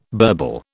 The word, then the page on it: burble: British English